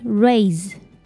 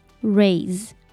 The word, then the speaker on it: raise: So the AI here pronounced A. And the SE here, you have to be careful because it sounds like Z. So it's raise, raise.